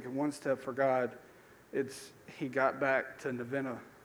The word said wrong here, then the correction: Nineveh: I'm glad I'm not the only one that cannot pronounce the Bible names. It's Nineveh, by the way. You'll never forget Nineveh now.